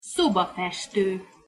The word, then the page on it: szobafesto: Hungarian